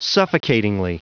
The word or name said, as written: suffocatingly